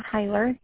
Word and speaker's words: Heiler: My name is Melissa Heiler. The last name is spelled H-E-I-L-E-R, and our policy is either under my name or my husband, Joseph.